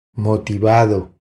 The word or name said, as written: Motivado